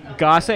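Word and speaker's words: Gose: Okay, so the bartender first said, well, the correct pronunciation is gosse. Gosse. – Gosse? – And I was like, are you sure about that? He's like, yes. Very adamant. Like, I know its correct pronunciation is gosse.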